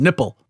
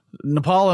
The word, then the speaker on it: Nepal: Yeah, I was mocking someone the other day for how Trump pronounced Nepal as nipple. Nepal and Bhutan, he couldn't pronounce. He pronounced them as nipple and button.